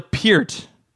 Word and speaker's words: Peart: You love that guy, even though you can't say his name right. Okay, what? Uh, how do how do you say his name right? How how do you pronounce that correctly? Uh, you it's Neil Peart.